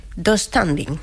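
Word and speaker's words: döstädning: Yo lo pronuncio... Dos standing. – Sí, así. Sí, tengo esa silla del IKEA, sí, pronuncia así.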